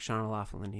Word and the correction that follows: Sean O'Loughlin: He's like, actually, it's the Gaelic pronunciation, Sean O'Loughlin.